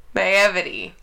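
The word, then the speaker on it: naïveté: Naivete is how you say it, right? – Naivete? – Yeah. No. Not the way she thinks. No, what is it? – Naivety.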